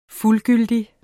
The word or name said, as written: fuldgyldig